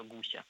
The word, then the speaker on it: гуся: Да, давайте еще с ударением разберемся, он все-таки гуся. – С гуся, я бы тоже сказал гуся, но орфоэпический словарь говорит, что гуся, как бы странно это не звучало.